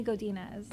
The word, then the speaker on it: Godinez: Aimee Godinez.